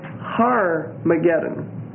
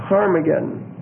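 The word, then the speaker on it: Harmagedon: And they do recognize the location of Armageddon, and they spell it Harmageddon. H-A-R hyphen M-A-G-E-D-O-N. So Harmageddon. H-A-R hyphen M-A-G-E-D-O-N.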